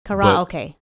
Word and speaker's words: karaoke: If you break down each syllable, it's karaoke. – Yeah. – Karaoke. Karaoke. – In Japanese, there's no emphasis on syllables. – No. – So you have to say it without emphasizing a syllable, which is tough as, you know, English, native English speakers, we emphasize syllables on everything.